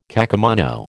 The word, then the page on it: kakemono: American English